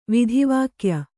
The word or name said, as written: ವೇದವಾಕ್ಯ